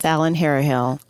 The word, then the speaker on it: Allen-Harrahill: It's Allen-Harrahill.